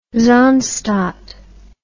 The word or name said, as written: zaanstad